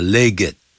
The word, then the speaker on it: legged: Before the age of mechanization, 在机械化的时代到来之前，就等于 before the coming of the age of mechanization, camels, horses, donkeys, and other four-legged creatures, 像骆驼啦、马啦、 donkeys, 驴子啦、 and other four-legged, 这个 legged, 啊，一定要那个 ED 啊发音的 legged, 好，我们就可以看得到的。